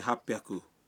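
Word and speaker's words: ہاپ پیاکو (八百): لیکن بعض اعداد کا تلفظ کچھ مختلف ہے۔ مثلاً 300 ہے سام بیاکو، 600 ہے روپ پیاکو، اور 800 کو کہتے ہیں ہاپ پیاکو۔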